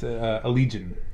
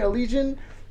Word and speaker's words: Allegaeon: No, it's actually a lot easier than you. It's Allegaeon. – Damn it. I kept saying Allegaeon,